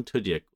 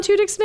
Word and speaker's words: Tudyk: Wait, how did you just say Alan Tudyk's name? – Tudyk? – Is that how you say his name? – Tudyk. – I thought it was Tudyk because that's a Y. Not a J.